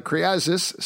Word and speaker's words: Kyriazis: and art by Elias Krizias. Uh, Kyriazis, sorry, I actually know him, so I should probably pronounce his name correctly.